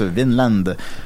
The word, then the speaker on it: Vinland: Alors voilà, donc c'est comme ça qu'on prononce Vinland.